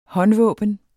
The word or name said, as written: håndvåben